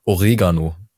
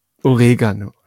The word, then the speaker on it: Oregano: Oder ja, nein, Entschuldigung, ich habe es schon wieder falsch ausgesprochen. Das heißt ja eigentlich Oregano. – Oregano.